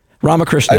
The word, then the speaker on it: Ramakrishnan: You got it almost right. Almost. Ramakrishnan. I know it's got a few syllables in it, so okay.